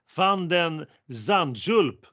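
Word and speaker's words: van de Zandschulp: Medvedev, han tappades ett set där faktiskt, men han vinner, och, men han vinner över överraskningen och Nederländaren, hör nu här på det här uttalet, van den Zandjulp med Z. Vilket uttal om du frågar mig? – Ja, hundraprocentigt.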